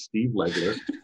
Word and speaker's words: Legler: It's only got one vowel in it. It's an E. It repeats four times. Steve Legler. it's spelled just like it sounds.